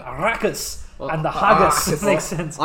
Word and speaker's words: Arrakis: And one of the lines mentions the planet that the most of the book is set on, and it's pronounced Arrakis. However, in the film, it becomes Arrakis. – Well, I can understand why, though, because there's two R's, so there should be more emphasis on the R's than the K, because there's one K in there. – You could try Arrakis.